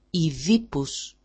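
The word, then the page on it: Οιδίπους: i5i-pus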